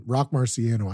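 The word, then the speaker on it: Roc Marciano: If you're into that kind of old school underground hip hop, if you're a big fan of Mark Rossi, or how do you say him? Rock Marciano.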